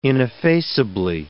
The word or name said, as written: ineffaceably